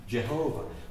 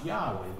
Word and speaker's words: Yahweh: Jehovah. We think it's probably pronounced Yahweh, but we're not 100% sure, because the Jews wouldn't pronounce it.